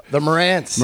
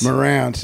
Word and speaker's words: Marantz: I'm always the Marantz. – Marantz, yeah.